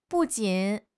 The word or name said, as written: bu4 jin3